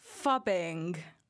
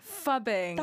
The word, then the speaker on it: phubbing: It's called fubbing. – Fubbing? – Fubbing. – That's not a term. – Can you spell it? – It's spelt PHUBBING. Fubbing.